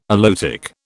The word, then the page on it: aloetic: British English